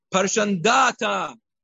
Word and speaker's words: Parshandata: It's a Persian name and it's pronounced like a Persian name. Nachon, Michael? Yeah? Parshandata Dalfon, Aspata, Porata, Adalia, Aridata, Parmashta, Arisai, Aridai, Vaizata.